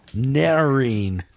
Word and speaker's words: Noreen: Noreen Kidd Shatner, Shatner's wife. Noreen rhymes with marine. She's a good swimmer. – Marine? Fish? – No, Noreen.